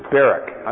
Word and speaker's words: barak: B-A-R-A-K. It's the same word as the name of Deborah's associate, Barak. Barak. I'm sorry, I didn't pronounce it as clearly as I should have. Barak, which means to bless or to praise, in the sense of kneeling and giving adoration.